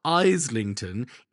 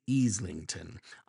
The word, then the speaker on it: Islington: My apologies go out to any residents of the London borough of Easlington, as I mispronounced it several times on the last episode, and I know I'll do it again today. It's not Islington, it's Easlington.